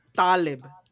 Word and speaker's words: Taleb: Porque se dice Taleb. Es con la tónica en la A y con las apretando poquito las narinas en la T, güey.